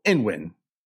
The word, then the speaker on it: Ng: NG is pronounced like ing every time I've heard it. We just have comment after comment about everyone's interpretation of how to say her name. So there's a soft N sound. So Inwin. That's how I was taught to pronounce it.